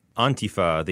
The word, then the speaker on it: Antifa: Antifa. The emphasis is on the first syllable.